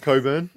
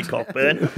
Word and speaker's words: Cockburn: In Cockburn, or how would you say it? – Coburn. Oh, yep. – I don't know why they all do it. All these Perth people, they won't say Cockburn.